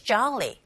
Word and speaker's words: jolly: Google Listening 1 Every second heading to is jolly. 네, 졸리보다는 jaw에 가깝게 오 사운드, 즉 오 철자의 강세가 가게 되면 아에 가깝게 발음하시면 됩니다.